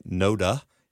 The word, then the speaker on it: NoDa: A lot of people now call it NoDa, you know, rhyming with coda.